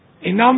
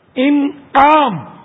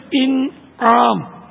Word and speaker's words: انعام: نہیں انعام بھی غلط ہے, انعام. چلو اردو بول چال میں تو انعام ہم بول دیتے ہیں کیا پکڑ ہوگی, لیکن صحیح تلفظ ہے انعام.